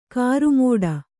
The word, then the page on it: ಕರ್ಮಠ: karmaṭha